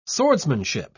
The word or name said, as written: swordsmanship